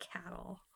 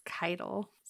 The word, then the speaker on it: Caiatl: Why are you pronouncing it cattle? You know it's kital. It's not cattle.